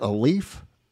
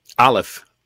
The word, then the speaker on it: Aleph: now the first one you got up there is how do you say that? A leaf? – Aleph.